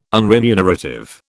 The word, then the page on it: unremunerative: British English